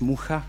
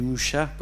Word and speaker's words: Mucha: Alphonse Mucha comme on le prononce en tchèque, et Mucha comme il devient euh, connu au monde entier, c'est exactement le cas.